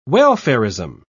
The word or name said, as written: welfarism